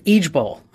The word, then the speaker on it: IJBOL: It's pronounced "each Bowl.